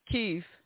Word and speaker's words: Kyiv: Kyiv.